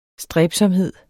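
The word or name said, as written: stræbsomhed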